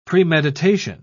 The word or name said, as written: premeditation